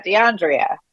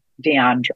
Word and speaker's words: De'Andrea: It's an Andrea, but it's spelled DeAndrea. And so it's fine. I think, again, that's also something cultural. My middle name is Andrea, and spelled Andrea. And so I naturally say DeAndrea.